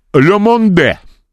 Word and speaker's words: Le Monde: Значит, газета «Le Monde» во французском не читается, если на конце «э» буква «э». И читается «le monde».